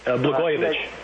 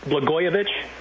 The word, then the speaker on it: Blagojevich: Blagojevich. – Say it again? – Blagojevich.